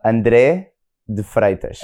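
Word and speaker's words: André de Freitas: But if I was to say it properly in Portuguese, it would be? – It would be Andre de Freitas.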